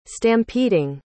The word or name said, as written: stampeding